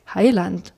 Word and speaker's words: Heiland: aber das Land reimt sich nicht, der, also die natürliche Betonung ist ja Heiland und hier reimt sich Heiland auf erkannt.